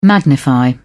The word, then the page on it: magnify: British English